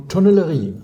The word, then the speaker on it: Tonnellerie: Tonnellerie.